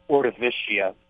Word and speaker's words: Ordovicia: I pronounce it Ordovicia, much like the Ordovician period in history or prehistory. – Okay, so you've got the soft C.